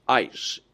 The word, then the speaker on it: eis: The Greek word translated unto is pronounced eis, E-I-S.